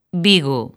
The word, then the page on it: Vigo: lingua galega